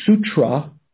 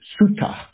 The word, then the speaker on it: sutta: So instead of saying sutra, where there's a T followed by an R, one in Pali is more easy to pronounce sutta.